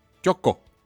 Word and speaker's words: Chocó: Chocó, porque Chocó sería con la acento de la hora.